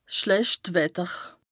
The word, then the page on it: 's schlecht Wetter: Bas-Rhin; Strasbourg